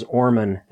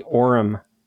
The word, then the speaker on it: Orem: Yeah, you pronounce the town's name as Orman. It's actually Orem, Utah. – Orem, yeah.